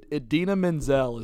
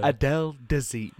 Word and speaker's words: Idina Menzel: I think we've brought this up on a podcast before, but when John Travolta fucking mispronounced that one chick's name. – Oh, my God. Idina Menzel is who he was trying to say. – Yeah. – Adele Dazeem.